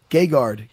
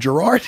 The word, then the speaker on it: Gegard: It's Gegard. How do you pronounce it again? – Gegard. – Gegard? – Yeah. – And what am I calling him? Gerard? – That's all right. He reads as Gerard.